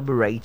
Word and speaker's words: beret: Beret is not beret, okay? So you put on the beret to church. So it's beret. One more time, beret. That's the way it's pronounced. And the last word for today is the word jewelry. Jewelry, not jewelry. J-E-W-E-L-R-Y.